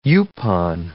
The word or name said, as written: yaupon